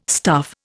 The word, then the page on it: stuff: Indian